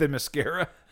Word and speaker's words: Themyscira: Who's Mysteria? – The place, the island. – Oh. – Wonder Woman's island. Did I butcher it? – Themyscira.